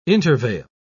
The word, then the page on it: intervale: British English